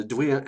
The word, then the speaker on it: Duille: There's a lot more, and part of that system is called the Duille, and it's spelled Duille. It's pronounced Duille. So L-E on the end is like a L.